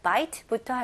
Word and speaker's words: bite: 여기서는 bite가 아니고요. 이름절로 깔끔하게 bite. bite. 이렇게 마지막 그 T 부분에 으라는 소리 안 나게 최대한 모음 소리 안 나게 깔끔하게 T로 끝내보세요.